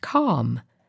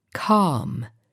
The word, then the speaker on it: calm: Let's look at the word calm. A lot of people have problems pronouncing this word, so I will repeat it a couple of times. Calm. Calm. It's the same pronunciation as palm, palma, or balm, balsam.